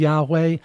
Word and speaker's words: Yahweh: Y-H-V-H. Some people pronounce that with the vowels then as Yahweh. Other people, Hebrew speakers especially, will say Hashem here or Adonai.